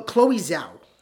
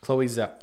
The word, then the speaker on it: Chloe Zhao: Zhang Chawa, you said? – Yeah, or or uh, Chao Chao Zhang? No, I'm not I'm not saying that right. – I just look up Eternal cast and you'll see Chloe, Chloe Zhao. Chloe Zhao.